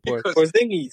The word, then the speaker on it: Porzingis: No, how do you say it? – Porzingis. – Porzingis, yes.